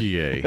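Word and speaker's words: Türkiye: Yeah, the like Turkiye or something. – Turkiye okay. Is the pronunciation the country of Turkey has asked the world to give them.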